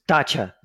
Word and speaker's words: dacha: Not the right pronunciation, but good knowledge anyway. – So explain what a dasher is. – Dacha.